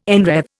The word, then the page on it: enwreathe: Indian